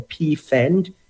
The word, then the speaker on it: Pfand: So in Germany, they have what we call a PFAND. It's like P with an F-A-N-D. It's like a fund, if you can pronounce it, without really, really breaking it down, because it's a German term.